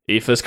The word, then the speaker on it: Aoife: So the first one is spelled A-O-I-F-E. How would you pronounce that? – Aoife. – Aoife's correct. – Oh, wowee. – Next one is S-I-O-B-H-A-N.